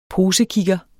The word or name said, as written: POSEKIGGEREN